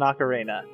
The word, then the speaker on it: ocarina: and then I have a conch ocarina, which I never pronounce right, but I'm calling it an ocarina. – That's right.